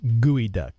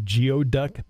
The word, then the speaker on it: geoduck: Geoduck, or it's actually pronounced gooey duck, okay, is a common type of giant clam used in the Pacific Northwest chowders. – Right. – It looks like geoduck, but it's gooey duck.